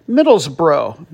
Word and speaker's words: Middlesbrough: We won't talk about the Hotspur, but we will talk about the team that we're less likely to know very much about, Middlesbrough. Make sure to pronounce it bro and not burrow to get it correctly.